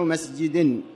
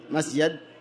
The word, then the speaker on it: مسجد: المساجد جمع مسجد كما تسمعون النطق، جمع مسجد. والمسجد بكسر الجيم كما تسمعونه، وهو المكان المخصص للصلاة، المكان المخصص للصلاة. ويجوز فتح الجيم مسجد، يعني يجوز فتحها، ويطلق على موضع السجود، سجود المصلي، وهو الجبهة، موضع الجبهة، وقوع الجبهة في الأرض.